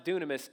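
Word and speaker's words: dunamis (δύναμις): it's pronounced dunamis, it looks like dynamis.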